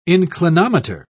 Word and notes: inclinometer: British English

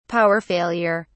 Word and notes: power failure: British English

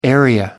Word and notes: area: American English

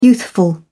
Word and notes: youthful: British English

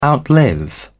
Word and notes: outlive: British English

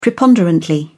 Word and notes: preponderantly: British English

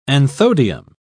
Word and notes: anthodium: British English

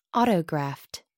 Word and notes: autograft: American English

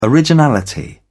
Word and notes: originality: British English

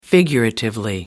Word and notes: figuratively: American English

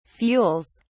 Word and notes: fuels: British English

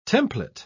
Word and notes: templet: British English

